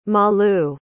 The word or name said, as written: malu